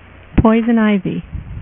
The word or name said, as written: Poison Ivy